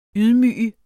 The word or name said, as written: YDMYGER